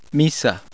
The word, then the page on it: i: Spanish